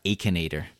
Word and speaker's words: Akinator: And this one's called, I believe it's pronounced Akinator. It's spelled A-K-I-N-A-T-O-R.